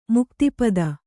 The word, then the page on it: ಮುಕ್ತಿಪದ: Kannada